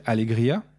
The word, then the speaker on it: Allegria: Et donc, du coup, on te reçoit parce que tu as écrit un livre qui s'appelle « Allegria ». – Ouais. – Je ne le prononce pas trop mal, ça va. – Non, c'est bien.